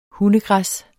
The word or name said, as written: hundegræs